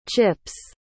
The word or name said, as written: chip's